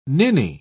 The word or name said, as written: ninny